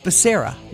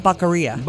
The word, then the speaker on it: Becerra: And the guy's name, he's the Health and Human Services Secretary, is Becerra. – Yep. – Not Baccaria. – Baccaria. – Now, it is spelled B-A-C-A-R-I-A.